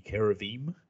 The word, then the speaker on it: cherubim: So we're talking high rank here, as this figure that we've come to know as Satan is elsewhere described as one of the cherubim, to pronounce it more correctly, the cherubim,